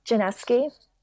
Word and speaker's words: Janiszewski: Januszewski.